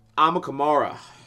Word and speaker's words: Amukamara: It'll be interesting to see with all these impact players that got drafted, like Fairley, Akamura, am I saying his name? Probably not saying his name right. Robert Quinn from the worst team in the NFC West. – Fighting St. Louis Rams. – I'm really stuck on getting this guy's name right. Amukamara.